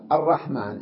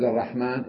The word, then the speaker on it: الرحمن: قال بعضهم الحذف في حال العلمية، هنا جاء علم، ومثله أيضا وإذا قيل لهم اسجدوا للرحمن، لم يذكر قبل الرحمن اسم الله حتى نقول الرحمن صفة له، بل رأسا اسجدوا للرحمن كما نقول اسجدوا لله، إذن هذا علم، فهنا لا نكتب الألف بعد الميم وقبل النون، تلفظ ولا تكتب.